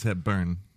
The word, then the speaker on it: Hepburn: Am I saying it right? Hepburn. – It's Hepburn. – Hepburn. – Hepburn. – Hepburn. – Yeah, emphasis on the U.